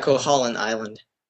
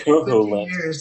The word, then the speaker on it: Koholint: Is that how you pronounce the island's name? – Yeah, Koholint Island. – Koholint. I don't say coconut.